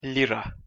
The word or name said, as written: lira